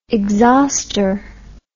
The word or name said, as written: exhauster